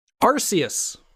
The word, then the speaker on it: Arceus: Yeah, so next week, yeah, I guess we could talk about our, how is it pronounced? Arceus or Arceus? – Arceus. – Arceus. – That's what I say. – I believe it is a soft C.